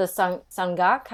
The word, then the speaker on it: sangak: Okay, I would say it was the sangak. How do you say that again? – Sangak. – Sangak.